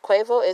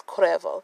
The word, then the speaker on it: Cuervo: Black people swear that drink is called Jose Cuervo. Cuervo is a member of the Migos, okay? It's not Cuervo, it's Cuervo.